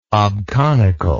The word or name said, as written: obconical